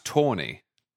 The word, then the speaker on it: Taney: And the other one is Rick Brookhiser told me that Roger spelt Taney, who wrote the Dred Scott decision, is actually pronounced Tawny.